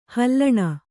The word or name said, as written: ಹಲ್ಲಣೆ